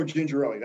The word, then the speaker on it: Gingerelli: Angelo Gingerelli, that'll work.